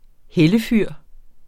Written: hellefyr

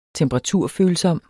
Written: temperaturfølsom

